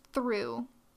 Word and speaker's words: Thoreau: But anyway, the closest transfer junction for the railroad lines in the Zunis was the town of, it's spelled Thoreau, Like T-H-O-R-E-A-U. But apparently it's pronounced through. I'm not sure why that is. But that's just how they like it down there. So, so basically from Albuquerque to Thoreau, or Thoreau, if you are civilized,